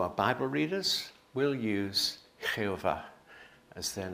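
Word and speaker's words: Jehová: And Spanish Bibles today and have always used Jehovah, which they pronounce Jehovah.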